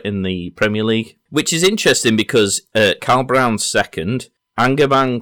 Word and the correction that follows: Aubameyang: from, how do you pronounce it? Angerman? – Aubameyang. – Aubameyang, thank you.